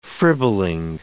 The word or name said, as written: fribbling